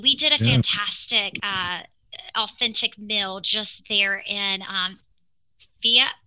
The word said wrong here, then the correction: Fira: was it? I'm going to say it wrong. Hold on. Fira. Sorry, they pronounce things differently.